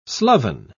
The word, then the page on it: sloven: British English